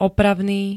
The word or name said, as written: opravný